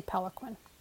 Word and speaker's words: Peloquin: I'll say Peloquin. That's P-E-L-O-Q-U-I-N.